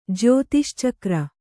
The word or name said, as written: ಜ್ಯೋತಿಶ್ಚಕ್ರ